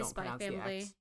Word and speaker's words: Spy x Family: Like, yeah, you just say Spy Family. Yeah, I am pronounced the X.